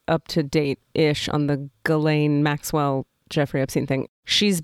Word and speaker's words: Ghislaine: You're probably saying it Ghislaine. – Right. – Ghislaine. Yeah. – It's Ghislaine.